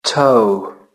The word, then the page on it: toe: British English